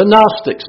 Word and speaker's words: Gnostics: The Gnostics. Do y'all remember the Gnostics? You remember that that G is silent.